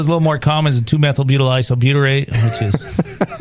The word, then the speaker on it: 2-methylbutyl isobutyrate: 2-methylbutyl isobutyrate.